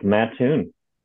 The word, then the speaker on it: Mattoon: It's Mattoon, right? Is that how you pronounce it? – It's Mattoon.